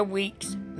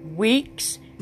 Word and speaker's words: Weekes: If you would like to support our ministry financially, you may do so by sending your tax-deductible gift to Andrea Weeks Ministries, and that is spelled A-N-D-R-I-A, Weeks, W-E-E-K-E-S, Ministries, at P.O. Box 373, Pinehurst, North Carolina, 28370. And Pinehurst is spelled P-I-N-E-H-U-R-S-T, one word.